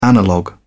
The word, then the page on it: analogue: British English